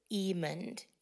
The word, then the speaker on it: Emond: There were a couple more who applied or disqualified or opted out, but we selected Robert Emond, E-M-O-N-D, Emond is how he pronounces his last name, and he is retired, so I, you know, maybe we'll find a new substitute or alternate liaison for 60 Plus.